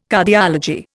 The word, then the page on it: Cardiology: Indian